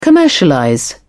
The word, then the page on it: commercialize: British English